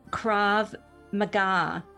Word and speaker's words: Krav Maga: He is a black belt third dan in Krav Maga. Is that the way you pronounce it? – Correct.